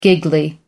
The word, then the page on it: giggly: American English